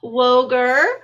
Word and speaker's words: Woegerer: With me today, I have the wonderful Dr. Barb Woegerer.